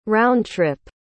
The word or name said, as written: roundtrip